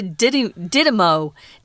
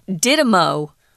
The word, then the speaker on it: Didymo: And I found out that most everyone just has shortened it to Didymo. Didymo and not even with the S, just Didymo.